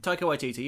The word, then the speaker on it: Taika Waititi: And from the second they got Taika Waititi, I always butcher his name. – Taika Waititi, you're right.